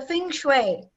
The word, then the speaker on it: feng shui: I put together that, well, it was a feng shui. – Yes, did I say it right? – Feng shui.